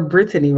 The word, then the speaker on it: Brittany: And I am Brittany Wright, or Brittany Wright. I am the youngest. – Yeah, we've never pronounced your name correctly your entire life, except for Genia. We just eliminated a whole syllable.